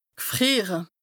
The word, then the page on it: g'frìere: Bas-Rhin; Strasbourg